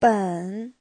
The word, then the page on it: 本: ben3